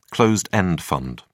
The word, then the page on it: closed-end fund: British English